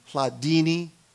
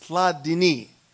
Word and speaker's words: Hladini: Please don't say Hladini. If it says fettuccine, capellini, it's not pronounced that way. It's Hladini. Please say Hladini.